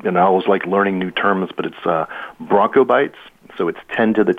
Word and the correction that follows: Bronto: Can you spell that? How do you spell that? Broncobytes? What's the spelling? – I believe it's Bronto, so B-R-O-N-T-O, byte.